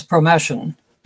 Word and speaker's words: Promethean: The one that I'd never heard of, and I have to look at the word to make sure I pronounce it correctly, Promethean? Is that it? – Yes, Promethean.